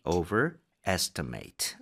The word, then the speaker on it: overestimate: Overestimate. 오케이, 둘 다 동사이기 때문에 much 아니라 mate로 발음하셔야 돼요.